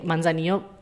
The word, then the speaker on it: Manzanillo: jadi Manzanillo jadi kalau double L itu dibacanya Y gitu somehow sama mereka.